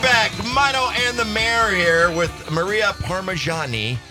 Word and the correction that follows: Parmigiani: Parmigiani. – Yeah, so Parma, like the region in Italy, and then Johnny, like a guy's name.